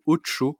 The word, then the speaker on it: OTXO: ça s'appelle Ocho. Alors ça s'écrit O-T-X-O.